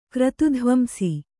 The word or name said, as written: ಕ್ರತುಧ್ವಂಸಿ